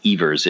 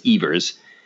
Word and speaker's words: Evers: I just learned it's actually pronounced Evers. It's spelled Evers, but it's Evers.